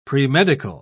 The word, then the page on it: premedical: British English